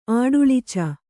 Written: ಆಡುಳಿಚ

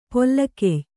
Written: ಪೊಲೆಗೆಯ್

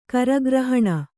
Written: ಕರಗ್ರಹಣ

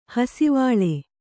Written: ಹಾಸುವಲೆ

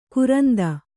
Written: ಕೋರಿಂದೆ

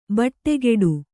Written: ಬಟ್ಟೆಗೆಡು

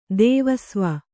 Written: ದೇವಸ್ವ

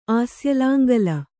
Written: ಆಸ್ಯಲಾಂಗಲ

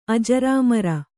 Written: ಅಜರಾಮರ

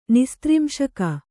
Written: ನೈಸ್ತ್ರಿಂಶಿಕ

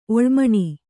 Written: ಒಳ್ಮಣಿ

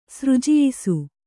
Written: ಸೃಜಯಿಸು

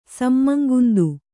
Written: ಸಮ್ಮಂಗುಂದು

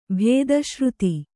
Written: ಭೇದಶ್ರುತಿ

